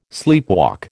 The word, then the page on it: sleepwalk: American English